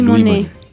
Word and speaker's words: Moinet: Ah, Louis, la marca, es Louis, Monet.